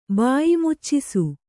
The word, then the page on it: ಬಾಯಿಮುಚ್ಚಿಸು: Kannada